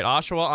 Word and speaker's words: Oshawa: So it's it's Oshawa. That's that's how you say it, right? Oshawa, Ontario.